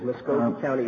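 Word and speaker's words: Muscogee County: Is it Muskogee County? Is that the way you pronounce it? – I believe it is. Muskogee County is split.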